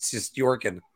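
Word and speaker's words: Shesterkin: So yeah, I just pronounced it your way. Actually, it's just Sestorkin.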